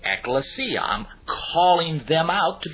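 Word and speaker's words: ekklesia (ἐκκλησία): Well, this word church comes from a Greek word that is pronounced ekklesia.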